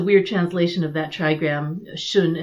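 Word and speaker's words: Xun: So hexagram 57, um, Sun, or Shun, I think is actually how you'd pronounce it, because in modern transliteration it's X-U-N.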